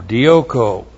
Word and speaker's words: dioko: D-I long O, K long O, dioko.